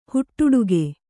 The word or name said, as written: ಹುಟ್ಟುಡುಗೆ